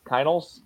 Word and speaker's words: Kienholz: Kienholz, yeah.